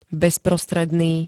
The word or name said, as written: bezprostredný